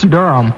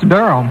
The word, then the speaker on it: Durham: Is it Durham? How do you say it? – It's Durham. – Durham. Do I say it right? – No, it's Durham. – I don't even hear the difference. Durham. Is that right? Durham. – That's about right.